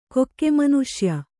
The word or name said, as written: ಕೊಕ್ಕೆಮನುಷ್ಯ